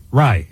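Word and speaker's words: Rai: Yeah, Aaron Rye. Is that how you say his name? – Rye.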